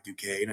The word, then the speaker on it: Duquesne: Now they, I had to correct them because I, you know, went to school by Duquesne. I said, it's Duquesne. So now they got kind of got their brand name and everyone in Florida knows who they are now.